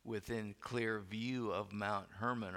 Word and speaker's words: Hermon: Mount Hermon, as the Jews pronounce it, the large mountain that stands as the border between Israel and the land of the country of Lebanon to the north.